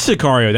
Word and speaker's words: Sicario: Is it, I don't know if I'm pronouncing this correctly, Sicario? – It is Sicario.